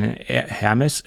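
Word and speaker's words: Hermès: Und dann haben wir noch gesagt, es gibt keine Stoffbänder von Hermes. Ich spreche mal das H mit aus. Es wird ja gar nicht mit ausgesprochen.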